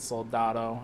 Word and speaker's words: Soldado: He wrote the sequel to Sicario, Sicario Day of the Soledad, mm-hmm. – or Soldado. However it's said. I know I just said it wrong, but it's Soldado, I believe.